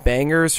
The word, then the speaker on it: Bangas: The beats are provided by Stu Bangas, and I will pronounce it Bangers from now on because the soft R hits my ears like a pile of wet towels dropped on a pile of wet towels.